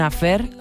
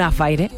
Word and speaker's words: affaire: Bueno, esto se llama Yo quiero un affair, así pensábamos decirlo hasta que escuchamos la letra y vemos cómo pronuncia Yo quiero un affaire, así que bueno, tendremos que ser fieles a su manera de pronunciarlo.